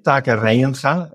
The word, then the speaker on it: Taka Reinga: Yeah, so my name, Taka Reinga, it's a name that I think only the Dutch can pronounce. I've heard plenty of variations, but you can call me Taka or anything close to that.